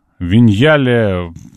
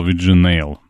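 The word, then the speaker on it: Vignale: Виньяле правильно читается ник, который я ранее читал, Виджинейл. Ну, будем так, Виньяле, запомню. Нет никаких лекарств от вирусов, прекратите ныть, что вам не дали, дорогой мел.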